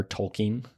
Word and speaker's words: Tolkien: Tolkien.